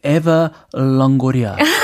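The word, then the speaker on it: Eva Longoria: 이거 맞아요, 발음이? – 에바 롱고리아. 사실 롱고리아 네. 전통적인 미국 이름 아니기 때문에 예, 발음이 약간 웃겨요. – 롱고리아. – 네. – 한글로 읽는 게 더 편해요.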